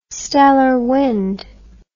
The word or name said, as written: stellar wind